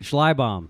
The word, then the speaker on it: Schleibaum: It was mangled by my ancestors who came over through Ellis Island. So it's not even the right spelling or the right pronunciation. – It's not. – But we're Americans. So that's how we say it. – So how do you say it? – Schleibaum. Okay, but I don't even know if I'm saying it right. But that's how I say it.